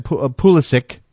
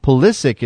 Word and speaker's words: Pulisic: Coming to you said Pulisic, by the way, Pulisic is how you say his name.